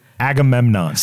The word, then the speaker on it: Agamemnon: How do you pronounce it? – Agamemnon. – Say it slow. – Agamemnon. Let me try to Ag. – Ag. – Eh. – Eh. – Mem. – Mem. – Non. – Agamemnon.